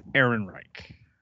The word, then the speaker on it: Ehrenreich: I'll help you with the pronunciation. It's Ehrenreich.